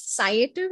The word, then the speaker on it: Sciative: So the term Sciative is pronounced as Sciative and not as Sciative or Sciative or Sciative, right? It is a combination of two words, science and creativity. So it is Sciative.